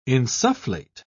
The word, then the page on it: insufflate: British English